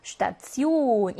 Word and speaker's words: Station: T, E, O, N 조합이 혹시라도 단어 안에 구성이 되어 있다라고 하면 이때 알파벳 T가 츠 발음을 냅니다. Station 이라고 발음해서 여기 있는 이 T가 트 발음이 더 이상 아니라 츠 발음을 냈다 하는 것만 기억 잘 해주시면 좋을 것 같네요.